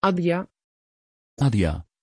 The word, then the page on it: adia: Dutch